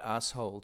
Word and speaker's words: asshole: You say the word asshole to rhyme with Picasso.